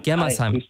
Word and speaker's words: Germersheim: Und jetzt ist aus Stuttgart am Start und er sagt, es ist Germersheim. Heißt das richtig? Germersheim? Habe ich es richtig gesagt? – Germersheim.